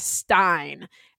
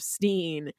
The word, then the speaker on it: Stein: And the lady in the courtroom pronounces his last name as Stein. And it's supposed to be so ridiculous, and everyone is supposed to get it because these ignorant hillbilly white folks think that Jewish last names that are spelled S-T-E-I-N are pronounced Stein instead of Steen.